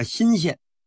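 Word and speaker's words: 莘县: 说没过几天呢，朱友贞呢派的这个太监又来到了这个新县。听众朋友们，这个新呢是草字头底下一个辛苦的辛，这个字啊是个多音字，也念社，念社也行啊，念新也行，都是地名。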